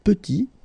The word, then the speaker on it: petit: And then we get petit. Il est petit, elle est petite. Okay, exactly the same idea. Final T is not pronounced. And then when you add this E at the end, you get the sound T, T, petite.